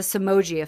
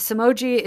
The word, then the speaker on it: Somogyi: So we're going to talk about the dawn phenomenon versus the Somogyi effect. And the Somogyi is spelled a really weird way. It's S-O-M-O-G-Y-I, Somogyi effect and the dawn phenomenon.